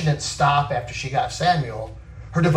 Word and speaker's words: Samuel: It's interesting because you look at the name Samuel. Okay, that's how we say it in English, but it really would be pronounced Shemuel, which Shem comes from the word Shema, right?